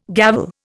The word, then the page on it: gavel: Indian